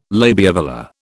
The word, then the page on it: labiovelar: British English